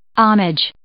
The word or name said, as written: homage